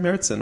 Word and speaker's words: Märzen: So we called up Dieter, and I read, like, these Oktoberfest styles are also called Märzens. And that's how I was pronouncing it, but I was never sure. Okay. I made that up on my own. It could have been completely wrong. And he's like, yeah, that's right, Märzen.